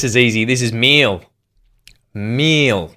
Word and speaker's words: Miele: And it's not exactly pronounced wrong in English, but English native speakers usually say something like Miele, Miele. So it usually sounds more like an A in the end, whereas in German we say Miele.